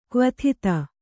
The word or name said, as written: ಕ್ವಥಿತ